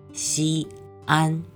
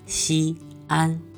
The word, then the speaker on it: Xi'an: It's written XI apostrophe AN. But most of the foreigners cannot pronounce it properly. Well, that's called Xi'an. Xi'an.